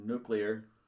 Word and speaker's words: nuclear: I can never remember the pronunciation for nuclear. – That wasn't too bad what you just did. – I can't remember if it's nuclear or nuclear. – Nuclear. Like, new clear.